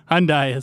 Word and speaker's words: Hyundai: It's Hyundai. – Hyundai. – I think that's how you pronounce it. – Hyundai is correct.